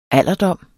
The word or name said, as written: alderdom